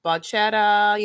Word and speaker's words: bachata: And where he's from, samba, like bachata, you know, I can't say the name right.